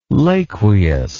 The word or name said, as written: laqueus